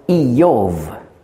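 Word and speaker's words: Iyov (איוב): Und tatsächlich, der Name Hiob heißt auf Deutsch, auf Hebräisch spricht man aus Iyov.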